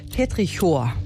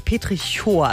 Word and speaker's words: Petrichor: Petrichor. Also ich hätte mir gedacht, das wäre Chor, aber stimmt nicht.